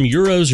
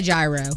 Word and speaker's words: gyro: I say gyro. – People in this country say gyro. Just because it looks like gyro. Euro is the actual correct pronunciation, but either is acceptable here.